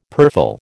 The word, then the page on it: purfle: American English